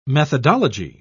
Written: methodology

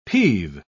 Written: peeve

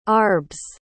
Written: arbs

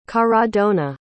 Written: caradonna

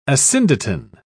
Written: asyndeton

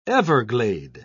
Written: everglade

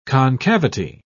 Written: concavity

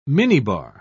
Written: minibar

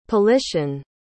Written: politian

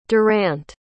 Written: durant

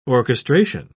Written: orchestration

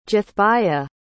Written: jathbiyya

